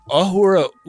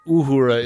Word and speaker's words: Uhura: I will say that Ahura Uhura is a really hard distinction to make just in my own mouth.